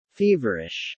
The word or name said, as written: feverish